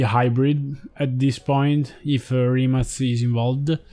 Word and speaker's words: Rimac: Also because Matte Rimac, or Rimac, I think that is the correct pronunciation in Croatian. – Yeah, it's Rimac.